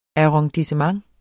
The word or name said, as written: arrondissement